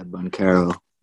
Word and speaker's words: Banchero: Yeah, Banchero.